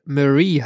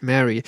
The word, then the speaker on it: Marie: Zumindest mir ist aufgefallen, dass die Aussprache ihres Vornamens tatsächlich, sie wird ja mit IE geschrieben und ich glaube, es müsste Marie heißen, nicht Mary.